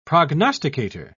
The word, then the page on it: prognosticator: British English